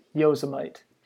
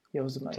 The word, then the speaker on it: Yosemite: Uh, and uh, once again, I believe it's pronounced Yosemite. Yosemite.